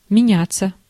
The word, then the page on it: меняться: IPA: /mʲɪˈnʲætːsə/; menjatsja